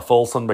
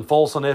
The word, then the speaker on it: Fulson: My last name is Fusen although in the music world I go by Fulson because nobody south of here can pronounce it the right way.